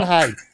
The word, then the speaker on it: Hyde: You got two great safeties in Hoyer and Pied. Did I say it right the time? Poyer and Hyde. Every time I say those guys' names together, I always say it wrong.